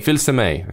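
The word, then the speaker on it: Fils-Aimé: So you're saying Filsame? – Filsame. – Okay, Filsame.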